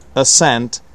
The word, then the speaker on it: ascent: Secondly, ascent, not ascent, ascent. Okay, ascent is when you go up. Descent is when you go down the hill or mountain, right? Ascent, descent. For some reason, I stressed the first syllable in this dialogue, and the stress is on the second one.